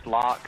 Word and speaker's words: Locke: Locke is spelled L-O-C-K-E, and my last name is Wheeler.